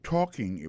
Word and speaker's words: talking: I'll be talking about, moi pral parler sous, I will be talking about, regardez bien pour le mot talking, non? Si c'est participe présent, conjugué au futur, c'est I'll be talking about, où accent tombé, accent tonique là, koto doué prononcer, tombé sous premier syllabe.